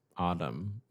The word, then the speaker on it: autumn: A-U-T-U-M-N? That's crazy. It should be spelled like this. O-D-D-U-M. Autumn.